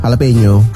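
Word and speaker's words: jalapeño: Jalapeno. – Hah? Bacanya gitu ya? – Iya, jalapeno. – Kok tulisannya kan jala? – Kan kalau orang Meksiko, orang Latin ngomongnya gitu. J jadi H.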